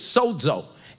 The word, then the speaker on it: sozo: And so I want to look at this word saved in the Greek, and the word is sozo, and it's spelled S-O-Z-O, but it's kind of spelled like soda, like sozo, okay?